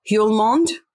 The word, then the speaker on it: Hjulmand: Lecce's Hummel. Oh, I never know how to say his name. Hjulmand.